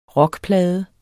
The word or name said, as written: rockplade